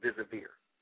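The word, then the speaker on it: deservir: That word deserve is a very powerful word that word comes from an old French word pronounced désavir.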